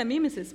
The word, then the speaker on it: mimesis: Jeg sier mimesis, men som I nemesis, liksom, samme måte.